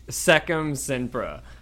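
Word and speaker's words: Sectumsempra: Serpententra? Can you? How do you say that spell? – Secum Sempra.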